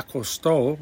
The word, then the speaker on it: acostó: mamá ya acostó, with the accent on the O, mamá ya acostó a los niños.